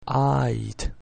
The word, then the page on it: ait: Latin